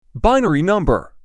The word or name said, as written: Binary number